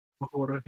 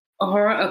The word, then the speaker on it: Uhura: But Michelle Nichols, who played Uhura, is that how you pronounce it? Uhura, yeah. Uhura.